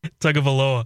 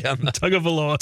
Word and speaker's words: Tagovailoa: Tagovailoa. – Tagovailoa. – Tagovailoa.